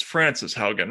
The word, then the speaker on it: Frances Haugen: So, okay, so first of all, who is it's Frances Haugen, right?